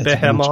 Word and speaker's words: Behemoth: But How do you pronounce that word? Behemoth. Behemoth. Yeah. Uh, it's Behemoth.